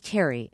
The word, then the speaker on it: Terry: My name is Terry, and I spell it T-E-R-R-Y.